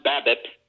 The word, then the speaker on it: BABIP: It's pronounced BABIP, and it stands for batting average of balls in play, okay.